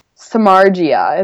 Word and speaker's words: Samargia: Samargia. It's like every little alphabet in there.